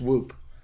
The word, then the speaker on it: Whoop: Can I tell you about the Swoop thing? Yes, you can tell me about your Swoop watch. – It's Whoop.